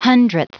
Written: 100th

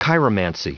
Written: chiromancy

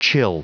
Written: chill

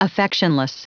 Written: affectionless

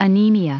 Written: anemia

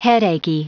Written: headachy

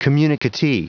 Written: communicatee

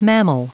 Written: mammal

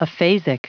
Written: aphasic

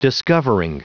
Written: discovering

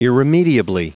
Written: irremediably

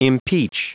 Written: impeach

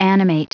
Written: animate

